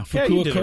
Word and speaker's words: Fukuoka: Fukuoka. – Oh, Fukuoka. – Yeah, Fukuoka.